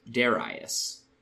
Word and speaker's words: Darius: When the Romans translated that Greek into Latin, they wrote it as D-A-R-I-U-S, a spelling which we have inherited in English. But it's more of a modern phenomenon that we pronounce D-A-R as dare, and there are a whole bunch of other ways I've heard modern English speakers say it. Darius, Darius, Darius. Classical Latin would have been Darius, and easily elides back into Darius if you're not thinking about it and speaking quickly.